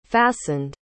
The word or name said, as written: fastened